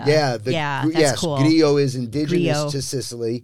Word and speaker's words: Grillo: Well, I guess if you're an American, you'd say Grillo, but it's actually pronounced Grillo in Italian.